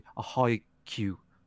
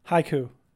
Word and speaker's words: haiku: A haiku. How do you say it? – Haiku. – Haiku. – Hi, like hi, and then ku as in cuckoo clock.